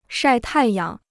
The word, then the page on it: 晒太阳: shài tài yáng